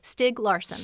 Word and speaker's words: Stieg Larsson: Stieg Larsson.